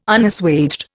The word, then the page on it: Unassuaged: Indian